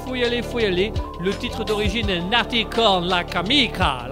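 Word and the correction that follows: Nati con la camicia: la camicia, ya, yeah, ici, merci. Dis-le l'italien si tu le prononces. Ah si, je vais le prononcer mieux que toi. Nati con de la camicia. Euh, c'est mieux tout d'un coup. C'est mieux, c'est mieux. Oui, effectivement, c'est mieux.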